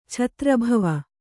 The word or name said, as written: ಛತ್ರಭವ